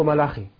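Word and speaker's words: ملاخي (מלאכי): وهنا كلمة ملاكي بالنص العبري ملاخي تنطق ملاخي أو ملاخي خاء وليست كاء، وهي توافق حروفا الكلمة العربية ملاكي، الميم واللام والكاف والياء، الياء للنسبة.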